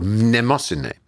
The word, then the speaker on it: Mnemosyne: And one of the original females was called Mnemosyne, which is spelled M-N-E-M-O-S-Y-N-E, Mnemosyne, which is the Greek word for memory.